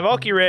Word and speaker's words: Valkyrie: Valkyrie. – Yeah, Valkyrie.